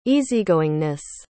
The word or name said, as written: easygoingness